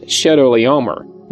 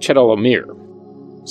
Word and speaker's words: Chedorlaomer: So it's really pronounced Chedorlaomer, not Chedorlaomer.